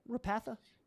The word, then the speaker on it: Repatha: Did I pronounce that right? Repatha? – Uh-huh. Yeah. Okay.